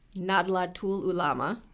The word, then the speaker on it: Nahdlatul Ulama: Arif's organization, Nadlatul Ulama, did I say that correctly? – Yes.